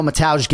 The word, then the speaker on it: Mateusz: All right, let's hear from Mateusz. – I'm Mateusz Rembetski. – All right, so it's spelled, you know, Mateusz, right? We all know Mateusz Gamrot, so certainly Poland, it's Mateusz. When it's M-A-T-E-U-S-Z,